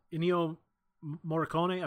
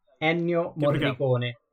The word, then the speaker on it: Ennio Morricone: The last fun fact I have for you, Ennio Morricone, am I saying that right? Help me out here. – No, you're not. Ennio Morricone. – Thank you. Thank you very much.